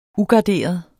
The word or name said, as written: ugarderet